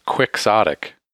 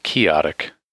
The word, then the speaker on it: quixotic: Apparently it is pronounced quixotic. And I always thought it was chaotic, because it's supposed to be Don Quixote, which right. Is what it comes from, but apparently it's quixotic.